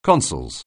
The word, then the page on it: consols: anglais